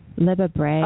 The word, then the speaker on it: Libba Bray: We wanted Libby Bray. – Libba Bray.